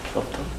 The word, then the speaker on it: subtle: See, it has to rhyme with Ertl. Subtle.